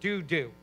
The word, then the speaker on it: Dudu: I don't think it had the same connotation that we do today, but in the Hebrew, that's exactly how you say it, Dudu.